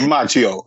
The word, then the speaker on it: Macchio: So, Macchiano. So, what did you think of that? – Yeah. Rob Macchio. Are you hungry? Rob Macchio. – Yeah. Gotcha. Sorry. Added an extra syllable there.